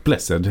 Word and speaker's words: Blessed: Och sen har vi också Brian Blessed, eller Blessed, eller hur det uttalas. – Ja, Blessed eh, tror jag faktiskt att det är.